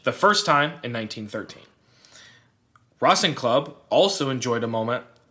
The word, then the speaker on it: Racing Club: Which literally just looks like Racing Club. – Yeah, but pronounced Racing Club.